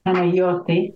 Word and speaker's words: Panayoti: His name is Panayoti Chopelas. Panayoti, is that how you pronounce it in Greek? – Panayoti, And that does mean Peter.